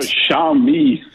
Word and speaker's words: Xiaomi: Schwammer. What's the Chinese technology firm Schwammer. Called that you like? Xiaomi. What? – Xiaomi. – Xiaomi. Thank you.